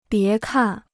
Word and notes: 别看: bié kàn

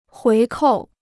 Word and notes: 回扣: huí kòu

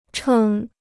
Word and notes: 称: chèn

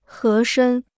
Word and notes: 合身: hé shēn

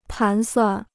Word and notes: 盘算: pán suàn

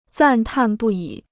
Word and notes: 赞叹不已: zàn tàn bù yǐ

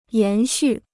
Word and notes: 延续: yán xù

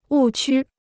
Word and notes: 误区: wù qū

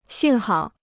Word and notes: 幸好: xìng hǎo

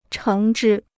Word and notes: 橙汁: chéng zhī

